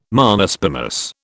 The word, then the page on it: Monospermous: British English